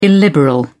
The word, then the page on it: illiberal: British English